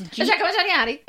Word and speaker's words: Giacomo Gianniotti: It's Giacomo Gianniotti.